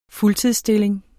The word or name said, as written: fuldtidsstilling